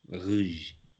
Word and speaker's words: Rouge: Is it Rose Rouge? How do you pronounce it? – Yeah, Rouge. – Rouge. I need to work on my. – Yeah, work on your French.